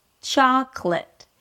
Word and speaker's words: chocolate: Now, as you can hear, there are only two syllables in this word, the way I'm pronouncing it. – Chocolate. – You start with a ch sound and then you open it to the a as in father. – Cha. – So it's not chocolate or chocolate. – Cha. – Cha. Drop your jaw, relax your lips completely, and then close it with a k sound.